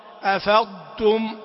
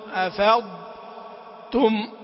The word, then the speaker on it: أفضتم: بالنسبة لأفضتم والضر خاصة، هذه تحتاج إلى عناية، لأنه في البعض ينطقها كالطاء يدغم، قل أفضتم أو أضطر أو خضتم أو فرضتم، يبادر اللسان إلى الإدغام وقلبها طاء. أفضتم، أفضتم، أفضتم.